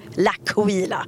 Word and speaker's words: L'Aquila: Och så bestämde oss för att det ska uttalas L'Aquila. Och än så länge är det typ bara jag som säger rätt. Alla andra säger fel.